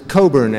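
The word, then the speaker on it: Cockburn: The name is Alistair Coburn, as in Coburn's Port, a very nice company that spends millions of dollars each year in advertising teaching people how to pronounce my name.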